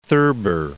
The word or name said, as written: Thurber